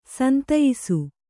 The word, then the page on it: ಸಂತಯ್ಸು: santaysu